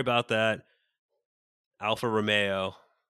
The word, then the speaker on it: Alfa Romeo: Romeo.